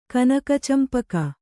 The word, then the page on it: ಕನಕಚಂಪಕ: kanak acampaka